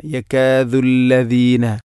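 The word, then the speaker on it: يكاد: ഇവിടെ വഇയകാദു ലദീന കഫറു, പ്രത്യേകിച്ചും ശ്രദ്ധിക്കേണ്ട ഒരു കാര്യമാണ്, വഇയകാദു ദാലാണ്, ദാലിന് പുള്ളിയില്ല, യകാദു അല്ല. ഓതി പോകുമ്പോൾ നമുക്ക് ശ്രദ്ധിക്കാൻ പറ്റും ഇങ്ങനെ വയ്യക്കാദുല്ലദീന, യക്കാദുല്ലദീന, ദാല് പൂർണ്ണമാകാതെ ദാല് വരുന്നു, യക്കാദുല്ലദീന.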